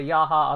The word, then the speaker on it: Yahya: The actor is, and I don't want to butcher his name. I really don't want to butcher his name, but it is Yahya. I'm going to say Yahya Abdul-Mateen II.